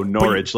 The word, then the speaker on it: Norwich: Am I overpronouncing the W? – I would describe it as Norwich. – Norwich. – Oh, Norwich. You take the W out of it. Yeah. You just pretend like it's not there.